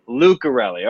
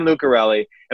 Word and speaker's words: Lucarelli: Is it Lucarelli or Lucarelli? I want to make sure I say it right. – Lucarelli. – Lucarelli. Okay, so Jen Lucarelli.